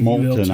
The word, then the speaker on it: Moulton: Is it Moulton or Moulton? It's M-O-U-L-T-O-N. – Moulton, I think that's how I've heard it pronounced.